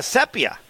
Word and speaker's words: Sepia: Sepia. That's Rogers' Homer. New name. It is Sepia, right? S-E-P-I-A. – Sepia. Sepia, Oh, you pronounce it Sepia? Okay. – Yeah, it's the color.